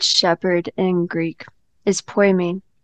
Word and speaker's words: Poimen: Poimen. P O I M E with a little dash on the line. That means it's a long E, hardy, and N. Poimen.